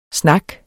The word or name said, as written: snak